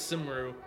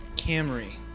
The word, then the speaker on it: Cymru: I thought it was Simru. – Simru, but I looked it up. It is actually pronounced Camry. – Okay. – C-Y-M-R-U is actually Welsh.